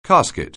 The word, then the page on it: casket: anglais